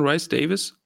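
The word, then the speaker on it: Rhys-Davies: John Rice Davis, wird er so ausgesprochen, mhm.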